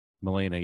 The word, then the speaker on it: Melina: Melina, right? – Melina, yeah.